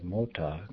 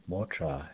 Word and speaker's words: mota: Words like mota, car, and shita, very, may be pronounced with an R sound in the second syllable, motra and shitra.